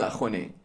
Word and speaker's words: Axone: Speaking of which, there is a movie called, I mean, again, it's random digression, but there's a movie called Akhone. I don't know if you watched it. – I've heard of this. – It's spelled A-X-O-N-E. Akhone is a...